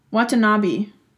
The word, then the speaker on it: Watanabe: It's by Kaoru Watanabe, and I'm sorry for butchering that name. – Watanabe. – Yes, Watanabe, that's right.